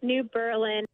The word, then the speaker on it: New Berlin: Yes, New Berlin, and we're about 10 miles from Springfield, Illinois.